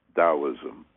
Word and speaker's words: Taoism: For those of you who are somewhat familiar with Chinese Taoism, pronounced like a D but spelled with a T, T-A-O, pronounced Taoism, you may know that was penned or written by Lao Tzu.